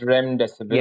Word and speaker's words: remdesivir: Uh, that is, uh, I, I, I may not be saying this right, but remdesivir. Oh, okay, I'll let you say that. But what remdesivir? Re- it's remdesivir. – Yes.